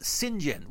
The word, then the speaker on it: St. John: it's Saint John Smith and but everybody pronounces it Sinjin, which I assume is just a British thing.